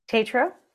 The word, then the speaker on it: Tetreault: Did I say that right? – Tetreault. – Tetreault. Okay.